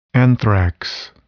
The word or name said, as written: anthrax